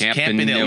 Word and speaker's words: Campanile: And his brother, and they kept pronouncing his name Campanile. So it's Campanile.